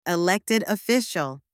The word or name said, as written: elected official